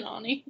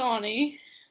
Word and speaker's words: Aune: Austin Aune. – Isn't it, like, Austin Ani? – It is Austin Ani. – No, well, I've never heard it pronounced before. – It's Ani.